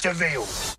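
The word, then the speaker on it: De Vil: He's spelled devil, but it's pronounced Deville.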